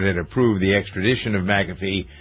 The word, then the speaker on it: McAfee: Victoria taught me how to pronounce his name. If you have a capital A, then that makes it more McAfee. – Pronounced. McAfee, apparently.